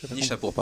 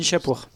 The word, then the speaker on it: Nishapur: Nashipur sur Nashipur. Non, tu faisais pas un geste, pardon. – Nishapur, pardon, excusez-moi. – Nishapur.